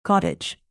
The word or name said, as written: cottage cheese